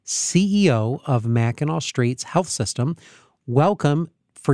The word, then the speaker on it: Mackinac: but it is Mackinac. So if you're looking at the show notes, don't worry, we didn't misspell it. It ends with a C, but it's pronounced Mackinac.